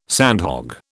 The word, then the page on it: sandhog: British English